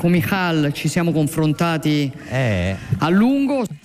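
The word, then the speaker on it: Micheál: Perché essendo irlandese uno pensa che si pronuncia all'inglese, si chiama invece, c'è scritto tipo Martin, invece si chiama Michal Martin, tipo alla toscana un po', però insomma.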